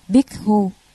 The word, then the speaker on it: Bhikkhu: Tỳ Khưu là tiếng Ấn Độ, nguyên âm đọc là Bhikkhu,